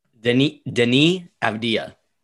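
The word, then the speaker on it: Deni Avdija: Denis Avdija.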